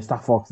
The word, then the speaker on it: Star Fox: Era uma coisa que o Star Fox estava buscando. É, Star Fox, né? Falei certo. – É, é.